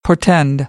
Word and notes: portend: American English

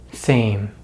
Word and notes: same: American English

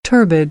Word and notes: turbid: American English

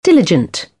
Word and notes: diligent: British English; ɪ

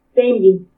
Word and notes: Témbini: Purépecha

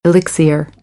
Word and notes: elixir: American English; ɚ - əɹ

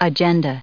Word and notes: agenda: noun

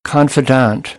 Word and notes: confidant: American English

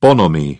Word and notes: bonhomie: British English

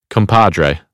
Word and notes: compadre: American English; ɑː

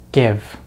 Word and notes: give: American English

